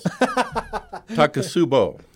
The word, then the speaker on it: Takotsubo: Takotsubo cardiomyopathy. Did I do good? – Close. Takotsubo.